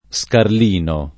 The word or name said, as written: Scarlino